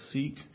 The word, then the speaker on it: Sikh: And I'm never sure how to pronounce the name of their religion. I believe you say it, a Sikh, S-I-K-H.